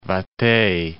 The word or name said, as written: Vate